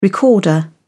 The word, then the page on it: recorder: British English